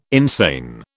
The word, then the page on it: insane: British English